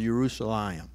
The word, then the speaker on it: Jerusalem: beginning from Jerusalem or Jerusalem. That's the actual pronunciation of Jerusalem.